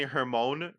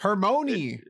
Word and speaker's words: Hermione: Years was calling Hermione Hermone because you like Hermione.